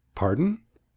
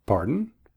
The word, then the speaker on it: pardon: Pardon. With the emphasis on the first syllable. Pardon.